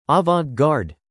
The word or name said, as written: avant-garde